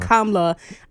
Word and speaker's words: Kamala: And same thing with Kamala. Same thing with Kamala. A lot of people were dragging her for that. – No, it's an accent mark. You accent mark. Kamala, like calm. Okay, calm, love.